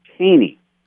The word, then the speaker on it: Thoene: So a couple years ago, you know, by God's providence, I met these authors, Bodie and Brock Taney, and it's spelled T-H-O-E-N-E.